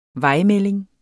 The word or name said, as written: vejmelding